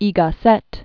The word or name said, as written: Ortega y Gasset